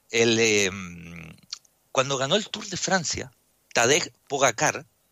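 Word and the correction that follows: Pogačar: Poda, eh, Pogachar se debería pronunciar según el esloveno, que es un chico de 22 años que acaba de ganar el Tour de Francia el domingo.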